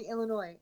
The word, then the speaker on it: Illinois: So most people that I know here say Illinois, and not there's no S at the end of it, because it's like the French. It's the silent last syllable.